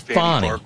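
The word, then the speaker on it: Fani: And wrapping up, Matt says, Fonny, and by the way, just a general reminder, that's how she pronounces her name. It is Swahili. She's not Fanny like in Fanny Flag. She's Fonny. Fonny.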